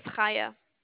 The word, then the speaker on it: Chaya: My name is Chaya. – Can you spell that? – Sure. C-H-A-Y-A.